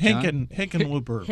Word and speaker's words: Hickenlooper: Is it Hickenlooper? – Hickenlooper.